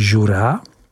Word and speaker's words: Jura: Prvním cílem dnešní výpravy je region Jura, vyslovuj Jura, jehož pojmenování pochází již od starých Keltů, kteří jej nazývali Jor.